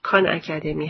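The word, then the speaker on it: Khan Academy: در این برنامه در مورد وبسایت خان اکادمی که تلفظ انگلیسی کان اکادمی هست و در سال 2006 با شعار روش جدید آموزش از طریق استفاده از فایل‌های تصویری به وجود اومده براتون صحبت می‌کنم.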